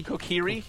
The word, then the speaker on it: Kokiri: Kokiri.